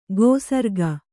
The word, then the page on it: ಗೋಸರ್ಗ: gōsarga